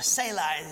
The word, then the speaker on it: Selah: Now, as we also noted, we have this this Selah, or Selah is the way it's pronounced in the Hebrew.